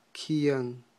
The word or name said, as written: kyan